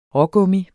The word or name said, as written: rågummi